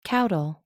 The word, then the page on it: caudal: American English